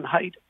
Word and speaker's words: Hyt: Well, um, my website is my name, Stan Height, and it's spelled H Y T.